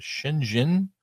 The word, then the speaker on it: Shenzhen: I think it was Shenzhen. – Yep. – Am I saying it right? – Yeah.